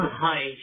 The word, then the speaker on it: Haisch: It's Haish. I said like Haish, but yeah, but it's a sh at the end, Haish.